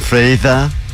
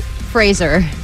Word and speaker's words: Fraser: He pretended he was delivering a coffee to Brendan Fraser. Is that how you say it? – So not right, no. – How does he say it? – Fraser.